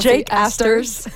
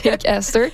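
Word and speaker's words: Jack Astor's: Donc c'est après la course au Jacques Astor, je l'ai prononcé vraiment français là, mais je pense Jake Astor. – Jake Astor.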